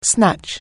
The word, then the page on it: snatch: anglais